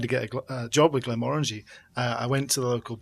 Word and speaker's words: Glenmorangie: and he heard me ordering it and he corrected me on the pronunciation. – Oh my gosh. – It's Glenmorangie.